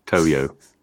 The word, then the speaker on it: Toyo: It's not a yo-yo. Toyo.